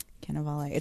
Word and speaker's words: Cannavale: His last name is Cannavale. We've been saying it wrong this whole time. I saw an interview with him the other day. – Cannavale.